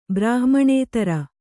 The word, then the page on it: ಬ್ರಾಹ್ಮಣೇತರ: brāhmaṇētara